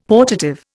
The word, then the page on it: portative: Indian